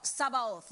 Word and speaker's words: Sabaoth: Jehovah Shabaoth uh, Sabaoth let me say it like that in Jewish that means he is the Lord of hosts.